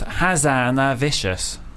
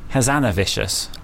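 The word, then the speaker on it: Hazanavicius: It actually is pronounced Hazanavicious. Hazanavicious.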